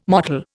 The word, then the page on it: mottle: Indian